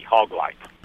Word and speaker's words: Hawglite: But it's spelled H-A-W-G-L-I-T-E, hog light.